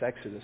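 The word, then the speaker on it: Exodus (ἔξοδος): That word for departure in Greek is the word Exodus. It's actually what it is in Greek. It's Exodus. It's how you pronounce it.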